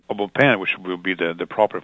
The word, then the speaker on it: Au Bon Pain: Or you can pronounce Au Bon Pain sometime here in Boston, which that's what the company originated.